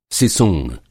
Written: सिसूंण

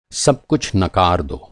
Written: नकार

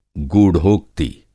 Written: गूढ़ोक्ति

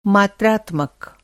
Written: मात्रात्मक

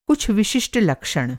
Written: लक्षण

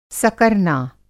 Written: सकरना

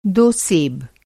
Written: सेब

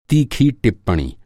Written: टिप्पणी

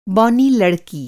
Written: बौना

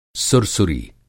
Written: सुरसुरी

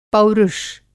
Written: पौरुष

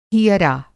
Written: हियरा

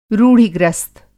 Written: रूढ़िग्रस्त

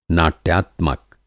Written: नाट्यात्मक

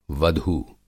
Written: वधू

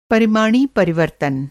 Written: पर

